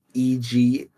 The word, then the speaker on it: Ejiofor: Chitwell Adigidafor. Yeah. Chitwell. I think that's how you say his name. – How you got that last name with Ejiofor.